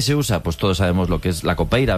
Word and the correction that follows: capoeira: ¿verdad? Capoeira. Qué mal pronuncio últimamente. – Capoeira.